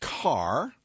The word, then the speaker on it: Kerr: He goes by Kerr, pronounced Carr, so I will correct myself, fitting for a man who will become famous for building roads.